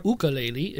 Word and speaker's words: ukulele: In this episode, we see Wayne Rogers playing the ukulele, or the ukulele, if you say it correctly.